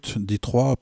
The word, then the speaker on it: Detroit: Et il vient aussi d'une ville, Detroit, pour prononcer à la française,